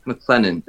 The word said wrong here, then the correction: McKellen: is that how you say his name? – Ian McKellen. – McKellen, yeah, said this in a TV show once.